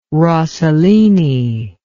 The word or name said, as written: rossellini